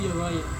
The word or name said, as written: yavai